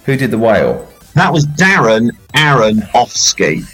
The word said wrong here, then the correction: Aronofsky: You can't say it like that. I'm not uh, allowing Darren that. Aronofsky. Darren Aronofsky. There you go.